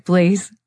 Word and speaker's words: Blaise: I'm not sure how, but I am on Facebook at Blaise Lantana, and it's spelled B-L-A-I-S-E, which is, by the way, a saint, in case people didn't know that.